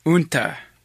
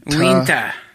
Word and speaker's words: Uinta: Good luck with this one. U-I-N-T-A-H. – Unta. – Unta. – Winta. – Winta. Winta County. – Winta County. – Winta? Is that what it is? How do you say it? – Uinta. – Uinta. You sure? – Yeah.